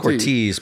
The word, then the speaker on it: Cortez: Is it Cortez? – That's Cortez, but yeah. I hear it pronounced differently.